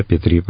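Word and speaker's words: Пе-3: И в самый сложный период, 41-42 годы, дал фронту, я себе выписал, 730 пикирующих бомбардировщиков П-2 и 134 дальних истребителей П-3. Правильно я произношу? – Правильно. П-2, П-3, правильно.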